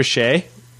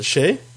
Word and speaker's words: Che: Like, did you just say Chi for Che? – Is it Che? How you spell it? – C-H-E, Todd.